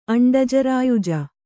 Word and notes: ಅಂಡಜರಾಯುಜ: aṇḍajarāyuja

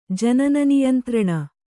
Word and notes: ಜನನನಿಯಂತ್ರಣ: Kannada

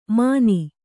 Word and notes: ಮಾನಿ: kannada

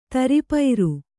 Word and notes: ತರಿಪೈರು: taripairu

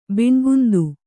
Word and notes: ಬಣ್ಣಗುಂದು: baṇṇagundu

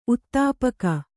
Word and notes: ಉತ್ತಾಪಕ: uttāpaka

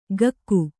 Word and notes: ಕಕ್ಕು: Kannada

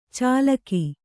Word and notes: ಚಾಲಾಕಿ: cālāki

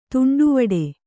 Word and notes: ತೊಂಡುವಾಡೆ: Kannada

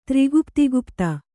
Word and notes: ತ್ರಿಗುಪ್ತಿಗುಪ್ತ: tri gupti gupta

